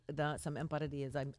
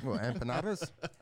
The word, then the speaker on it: empanadilla: empanadas? – That's not how you pronounce it. That's not how you do it? – No, it's empanadilla.